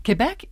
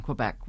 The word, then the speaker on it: Quebec: Quebec is how the Francophones in Quebec would describe it. I'm an Anglophone, so I call it Quebec. So, but either is right.